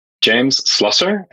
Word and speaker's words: James Slusser: James Slusser, and the company is Slusser.